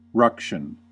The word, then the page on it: ruction: American English